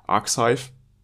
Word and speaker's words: arXiv: Die haben nämlich Ende Januar eine Vorabstudie auf dem Preprint-Server, ich hoffe, ich, das spricht man so auf, aus, Arxiv, A-R-X-I-V, bestimmt, veröffentlicht.